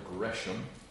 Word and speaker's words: Gresham: Gresham, that is how his name is pronounced. He clarifies that. It's spelled Gresham, but it's pronounced as Gresham.